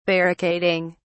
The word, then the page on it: barricading: British English